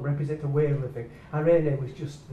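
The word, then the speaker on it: irene (εἰρήνη): And in the New Testament, that word is irene. It's pronounced irene.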